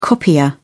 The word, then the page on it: copier: British English